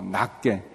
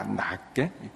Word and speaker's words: 낫게: 겸손한 마음으로 자기보다 다른 사람을 더 낮게 이제 제가 이 발음이 안 좋아서 그러는데 이 낫이 이제 나에다가 시읒인데 그걸 우리는 지읒으로 나, 낫게 이렇게, 구별이 안 되는데 제가 어, 발음을 잘못 이게 약한데요. 아무튼 우리가 그렇게 이제 더 높게 여기라는 건데 더 훌륭하게 여기라는데 더 존중해 주라는 건데 우리는 그 지읒으로 바꿔서 나보다 낮게 이렇게 높이 는게 똑같이 들리죠. 아무튼 그 길게 발음해야 되나?